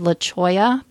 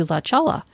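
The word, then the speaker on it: La Cholla: So there's a road here that's called La Choya, but it looks like if you were to try to read it phonetically, it would look like La Chala. And of course, you know, that's how Siri pronounces it, like turn right onto La Chala.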